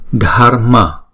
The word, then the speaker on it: Dharma: Chỉ đọc là Dharma, Dharma. Không có đọc rõ là chữ R mà là đánh lưỡi thôi.